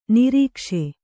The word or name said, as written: ನೊರಕಿಸು